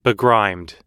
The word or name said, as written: begrimed